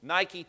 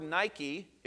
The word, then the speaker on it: Nike: Many of you may own Nike tennis shoes, and the word Nike is the mispronounced version of the Greek word, which is nike, which means victory.